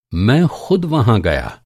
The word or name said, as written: ख़ुद